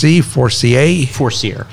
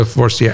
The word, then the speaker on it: Forcier: And now your name is pronounced Forcier. Forcier. – Yeah, like a sear steak. – So Forcier.